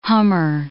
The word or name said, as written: hummer